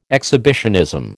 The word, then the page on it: exhibitionism: American English